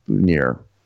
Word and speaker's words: boutonniere: But apparently, at least in America, it's pronounced boutonniere.